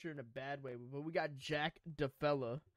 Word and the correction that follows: Della Maddalena: versus Randy Brown. – Jack Della Maddalena.